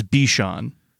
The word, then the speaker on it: Bichon: The word is Bichon. It is Cream Danger Bichon.